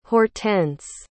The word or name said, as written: hortense